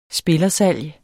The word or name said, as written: spillersalg